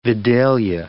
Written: vedalia

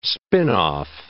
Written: spin-off

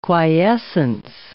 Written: quiescence

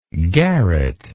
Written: garrett

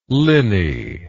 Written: linhay

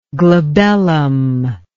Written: glabellum

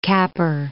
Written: capper

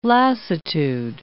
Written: lassitude

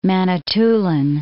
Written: Manitoulin Island

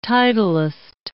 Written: titlist

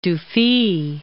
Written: Dufy

